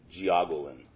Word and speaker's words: jiaogulan: It's spelled J-I-A-O-G-U-L-A-N, okay? – And the pronunciation? – Geoglin.